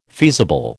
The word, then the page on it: feasible: American English